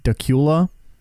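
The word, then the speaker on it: Dacula: There's a place in Georgia that's spelt like, it, I don't know how to spell it, but it's pronounced Dakula. And I'm like, that's got to be wrong. And there's no way some place is named Dakula.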